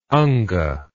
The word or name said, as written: anga